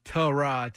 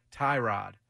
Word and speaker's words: Tyrod: The proper pronunciation for Tyrod Taylor is Tyrod Taylor, not Tyrod.